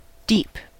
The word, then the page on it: deep: /diːp/; English